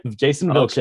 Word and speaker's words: Jason Vilches: I hope I said that right. – Yep. Jason Vilches.